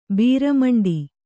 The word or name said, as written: ಬೀರಮಂಡಿ